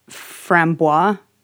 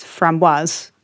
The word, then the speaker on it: framboise: And we call it frambois here. – Yeah. – We just make it sound French. But it's actually, it's O-I-S-E and it's pronounced framboise.